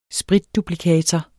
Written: spritduplikator

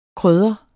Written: KRYDDERNE

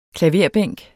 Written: klaverbænk

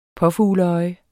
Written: påfugleøje

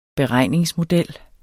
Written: beregningsmodel

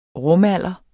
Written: rumalder

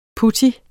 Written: putto

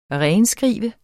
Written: renskrive

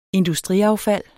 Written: industriaffald

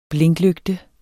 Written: blinklygte

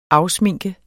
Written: afsminke

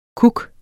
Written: kuk

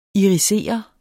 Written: IRISERER

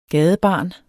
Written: gadebarn

